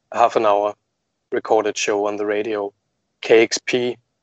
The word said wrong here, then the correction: KEXP: am I pronouncing it correctly? – Yeah, it's KEXP, right?